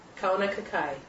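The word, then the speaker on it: Kaunakakai: Help me pronounce it. – Kauna Kakai.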